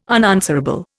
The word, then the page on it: unanswerable: Indian